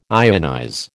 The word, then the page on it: ionize: American English